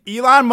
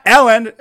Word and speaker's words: Elon: Yeah, it's Elon. – It's spelled what you think it was? – Ellen. E-L-E-L-O-N. – It's not Ellen. Like Ellen DeGeneres? – Yeah. I thought it was pronounced Ellen. – No.